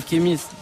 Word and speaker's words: Khemis: Bienvenue à toi, comment tu t'appelles Je m'appelle Kémis. Comment Kémis. Kémis, comment ça s'écrit, dis-moi K-H-E-M-I-S.